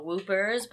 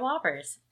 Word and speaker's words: Whoppers: I'm eating Whoppers, or what I like to call Whoopers, but they make Josh mad when I mispronounce them, so they are Whoppers.